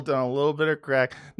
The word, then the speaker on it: craic: Yeah, had some good crack. They're all hooked on crack over there, but it's spelled C-R-A-I-C. No, they smoke crack. Yeah. C-R-A-I-C. That's their word for fun.